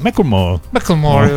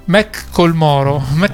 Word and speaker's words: Macklemore: Macklemore, lo pronuncerei così. – Va bene, dai. – Macklemore.